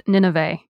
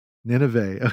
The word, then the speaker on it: Nynaeve: On the audiobook, it's pronounced Nineveh. – Nineveh, okay.